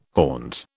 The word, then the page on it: haunt: British English